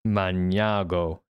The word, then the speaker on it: Magnago: to Cassano Magnano. Magnago is how you say it, actually.